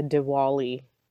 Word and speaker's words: Diwali: W with the Diwali. Deepavali is with a V. Oh. One's South Indian, one's North Indian. So that's why you hear Diwali, because that's North Indian.